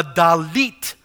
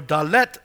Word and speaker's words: Dalet: And this evening we'll be looking at the Dalit or Dalet section, depending how you want to pronounce that.